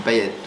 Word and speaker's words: Payet: Non, non, c'est à La Réunion d'où je viens, ça se dit Payette.